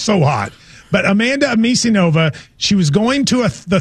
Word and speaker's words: Anisimova: Anisimova. – Anisimova.